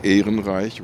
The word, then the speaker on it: Ehrenreich: Is it actually a similar crowd as I would pronounce her name Barbara Ehrenreich, which is the German.